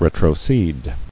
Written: Retrocession

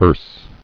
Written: Erse

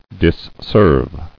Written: Disserve

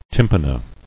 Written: Tympana

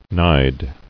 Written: Nide

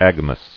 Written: Agamous

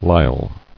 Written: Lisle

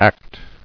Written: Actable

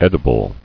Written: Edibility

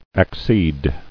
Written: Acceding

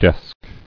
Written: Desked

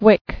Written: Wicking

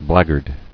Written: Blackguardism

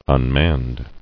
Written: Unmanned

